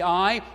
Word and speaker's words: aye: The word in the final phrase of the hymn is the word A, pronounced like E-H, but spelled differently, spelled A-Y-E.